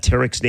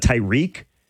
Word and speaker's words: Tarek: And by the way, very quickly, Gary and Wojo, were you guys amazed how many people got Tarek's name wrong this weekend? I heard a lot of Tyreek. – Really? – People don't know how to pronounce Tarek Skubal.